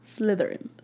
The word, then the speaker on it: Slytherin: Slithering Slithering Sí, eso.